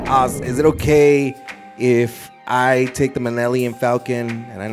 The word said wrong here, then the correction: Millennium Falcon: Millennium Falcon.